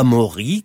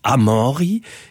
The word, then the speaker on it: Amaury: À Maury qui s'écrit A-M-A-U-R-Y. Et que se disiot avait amori, et sabes que n'occita, un abori, accueil une drôle, qu'est une moussi, qu'est pas complètement acabat.